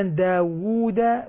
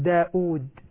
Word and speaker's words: داود: أولا داود واو مضمومة وبعدها واو مادية، ولا نقول داود. كثير هذه من الخطأ، هذا منتشر بين الناس، كثير من الناس ينطقون الكلمة بالهمز، وهذه لم ترد أصلا في أي قراءة من القراءات، كلها كله مجموعون على داود.